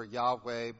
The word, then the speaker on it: Yahweh: Jehovah is how scholars pronounced it. Olden days, nowadays they prefer Yahweh, but historically the Y in Hebrew is a J sound.